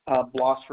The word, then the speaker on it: Blas: Blas Resendez. – Can you spell that for me, please? – B-L-A-S is my first name, last name.